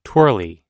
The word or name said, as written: twirly